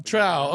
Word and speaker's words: Trowe: How am I pronouncing that last name right, Paul? Am I getting that right? – It's Trowe.